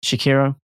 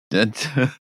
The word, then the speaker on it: Sekiro: Did I say that right? Yes. Shakiro. – Sekiro. Shakiro. Shakira. Shakira. Shakira. Not Shakira. – It's not. There is no relation to Shakira at all.